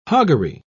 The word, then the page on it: hoggery: British English